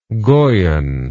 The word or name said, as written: goyen